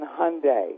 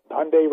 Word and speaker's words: Hyundai: So I have to say, just think Sunday and then Hyundai. – That's right. Yes. – Okay? – Yes. – So it's the Hyundai. – Hyundai rhymes with Sunday.